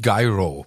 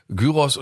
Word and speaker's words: Gyros: Also Aber da, die hatten wir doch damals schon geschockt mit der, erinnerst du dich mit der amerikanischen Aussprache? Weil sie hatte mich auch geschockt in Amerika, nämlich Gyro. – Oh, äh, äh oh. – also ich bestelle nicht Gyro, sondern Gyros oder nächstes Mal mache ich Heros.